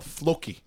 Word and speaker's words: Floki: It's called Floki. – Floki? – F-L-O-K-I with the I having an accent mark and a long I. So no, the O. Sorry, the O. The I doesn't. Floki. – Floka. – Floki. – Floki. Like, yeah, Floki.